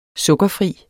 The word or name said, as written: sukkerfri